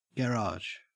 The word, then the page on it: garage: Australian English